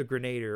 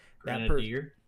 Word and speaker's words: grenadier: The the grenader, or however you pronounced it. – Grenadier.